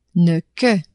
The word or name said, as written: ne-que